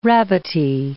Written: rabbity

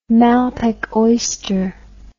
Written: malpeque oyster

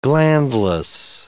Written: glandless

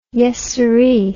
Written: yessiree

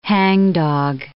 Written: hangdog

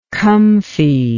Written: comfiness

